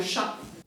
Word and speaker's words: chas: Le petit trou de l'aiguille, là, ça s'appelle le chat. – Le chat de l'aiguille, savez-vous l'écrire ? Rien à voir avec l'animal. Ça s'écrit C-H-A-S.